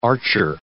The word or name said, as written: archer